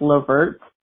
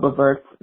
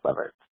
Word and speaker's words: Levert: It's pronounced Levert. It's Gerald Levert, not Levert.